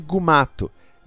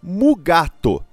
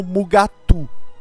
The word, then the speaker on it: Mugato: Se você olha os créditos, o Janus Prohaska, o ator, foi creditado como Gumato, o nome original. Por conta disso, todas as pronúncias são cânone. Gumato, que estava no roteiro, Mugato, que é como chamavam, além das formas erradas com o U, Mugatu, Gumato e Mugutu.